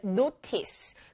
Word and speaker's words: notice: 원래 notice. 근데 notice 영어로 할 때는 T가 굉장히 약하게 발음되죠.